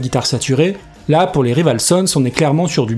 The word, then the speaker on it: Rival Sons: Alors on va se mettre d'accord tout de suite, oui, ça se dit Rival Sons, mais je vais le prononcer à la française, je gagnerai un petit peu de temps et un petit peu d'énergie.